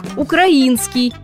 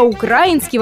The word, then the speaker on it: украинский: Но по современным правилам русского языка нормативным является только ударение на третий слог – украинский. А украинский во многих словарях отмечается как ошибочный вариант.